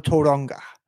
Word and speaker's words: Tauranga: I can't wait to bring up the debate we had when we got to Tauranga.